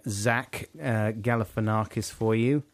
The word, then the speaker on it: Galifianakis: And I extremely confidently said, no, it's pronounced Galifianakis. And there was a few messages from people saying... – Saying that you were Rongo Snodgrass. – Rongoloid. It's pronounced Zach Galifianakis.